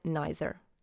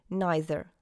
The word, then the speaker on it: neither: Тоже с произношением neither. Neither. С долгим звуком «i» в начале British English. Neither. С долгим звуком «и» в начале American English.